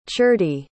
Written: ciardi

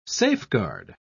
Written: safeguard

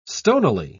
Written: stonily

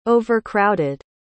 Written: overcrowded

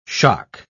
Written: shock

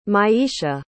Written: myiesha

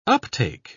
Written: uptake

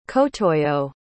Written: kotoyo

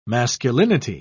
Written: masculinity

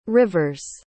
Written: rivers

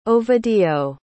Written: ovidio

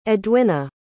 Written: edwinna

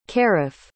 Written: caraf

